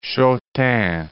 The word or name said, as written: Chautemps